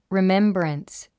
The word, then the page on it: remembrance: rimémbrəns; リメンブランス